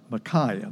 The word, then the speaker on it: Micaiah: This Achabor, the son of Micaiah. That's how you pronounce his name, Micaiah.